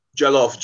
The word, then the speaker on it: Jollof: Isn't I might butcher the same, but isn't Jola pretty big down there too? Is it Jola or Jala? – Jollof. – Jollof, yes, that's it. – Jollof is West Africa. Now you're talking about the Jollof is West Africa.